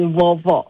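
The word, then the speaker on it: uovo: 嗯，它其实确实它是意大利文，然后它的发音是 Vovo。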